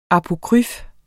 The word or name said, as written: apokryf